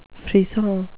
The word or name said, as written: frisson